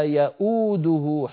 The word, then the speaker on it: يؤوده: أيضا مر معنا في آية الكرسي ولا يؤوده حفظهما سبحانه وتعالى، انظروا إليها من كان منكم يفتح مصحفا، المفروض أن تكتب واوان، الواو الأولى كرسي للهمزة والواو الثانية هي الواو المنطوقة يأو، أنا لما أقول يأو أنطق همزة مضمومة وبعدها واو